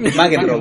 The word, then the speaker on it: McEnroe: Sí, sí, Aprende a pronunciar el apellido por weón. McEnroe. McEnroe, sí.